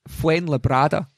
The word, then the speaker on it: Fuenlabrada: I've been putting an N in where there wasn't an N. so Fuen Fuen Labrada um, have won.